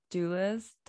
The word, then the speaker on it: Dulles: Yeah, it's Dulles. – It's pronounced Dulles, Dulles.